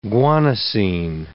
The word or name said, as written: guanosine